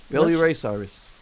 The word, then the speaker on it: Billy Ray Cyrus: And we want to put this song from the singer Bill Ryan Cyrus? – Billy Ray Cyrus. – Okay, see, you pronounce it much better than me.